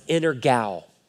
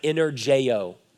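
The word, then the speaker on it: energao (ἐνεργέω): That is a Greek word, and it's pronounced energao. Energao. It looks like energeo. Energeo.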